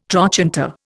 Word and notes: trochanter: Indian

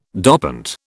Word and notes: dopant: British English

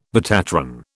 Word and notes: betatron: British English

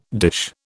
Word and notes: dish: British English

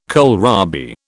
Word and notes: kohlrabi: British English

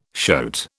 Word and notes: shoat: British English

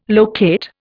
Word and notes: locate: Indian

